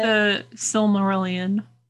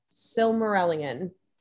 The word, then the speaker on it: The Silmarillion: The Silmarillion. – Silmarillion.